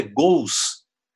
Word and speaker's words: gols: O gol está é, tá incorporado, mas o plural é gols, mas na escrita é LS. Né? Nós já não pronunciamos mais o L no final de sílaba e de palavras, né? os brasileiros não pronunciam mais, e mas diferente dos portugueses que colocaram uma vogal aí, né?